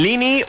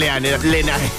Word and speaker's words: Lenae: Ahí estaba Raven Lini, o como se pronuncie, me vais a funar igual, así que Raven Lini. O Raven Lenae, que creo que se escribe. Venga, Raven Lenae.